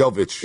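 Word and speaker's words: Jović: As you watch 20-year-old Jovic, am I even pronouncing that right? – Jovic.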